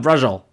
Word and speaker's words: braciole: So, B-R-A-C-I-O-L-E? – B-R-A-C-I-O-L-E. Yep. – We can do this off the. – Brajol.